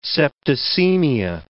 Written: septicemia

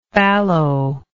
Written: fallowness

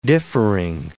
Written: differing

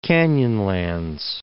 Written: Canyonlands National Park

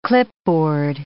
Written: clipboard

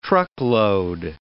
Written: truckload

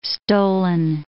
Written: stolen

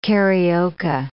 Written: carioca

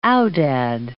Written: aoudad

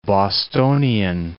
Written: Bostonian

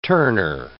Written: Turner

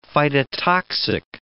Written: phytotoxic

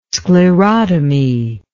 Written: sclerotomy

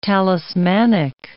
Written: talismanic

